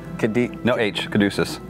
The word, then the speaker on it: Caduceus: Caduceus.